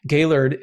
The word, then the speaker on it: Gaillard: Rusty, R-U-S-T-Y. Gaylord is G-A-I-L-L-A-R-D.